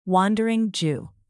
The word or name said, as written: wandering jew